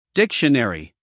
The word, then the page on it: dictionary: American English; male